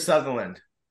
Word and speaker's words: Sutherland: So that was a great film clip of Anthony in his young days interviewing Kiefer Sunderland. I don't know if I'm saying it right. And so welcome. What is it? – Kiefer Sutherland.